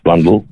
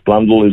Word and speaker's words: Blundell: So it should rhyme with bundle. And don't ask me why. Any phonetic will tell you that should be Dell at the end, but that's just how we got branded, and it's Blundell is the correct pronunciation.